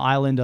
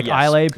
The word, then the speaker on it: Islay: and then you finish on the island of Islay, I believe it's pronounced. – Islay? – It's ISLES, whatever it is. – Yeah, AY. And people might pronounce it Islay, but it's actually Isla.